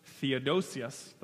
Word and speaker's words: Theodosius: So there's this guy named Theodos, I'll say it right, Theodosius the First.